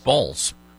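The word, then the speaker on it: Baals: Do we know if they actually did decide to name their, because it's a B-A-A-L-S is the actual spelling, but the pronunciation is in fact Balls.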